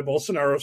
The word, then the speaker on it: Bolsonaro: Bolsonaro? I don't know how to pronounce Bolsonaro. – That. – Bolsonaro. You had it right.